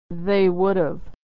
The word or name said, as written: they would've